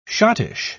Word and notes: schottische: British English

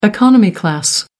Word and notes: economy class: American English

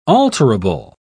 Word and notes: alterable: British English

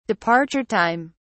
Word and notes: departure time: British English

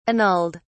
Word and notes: annulled: British English

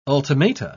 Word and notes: ultimata: British English

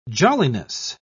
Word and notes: jolliness: British English

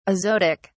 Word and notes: azotic: British English

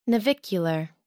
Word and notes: navicular: American English

